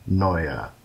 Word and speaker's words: Anoia: That is spelled A-N-O-I-A. Anoia. Excuse me. – Okay. – Anoia. Sorry, the emphasis is on the noi, and it's an on both sides, a U-A.